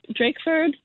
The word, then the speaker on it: Drakeford: Drake Drakeford.